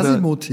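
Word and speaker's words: Azimut: Asimut, ja, ja, so wird das ausgesprochen.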